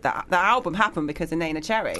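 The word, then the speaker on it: Neneh Cherry: And also, I just got to applaud you for the way you pronounce Nana Cherry, because most people say Nina Cherry. – Oh, no. Nina? – A lot of people say, honestly, a lot of, you'd be surprised how many people pronounce her name Nina Cherry.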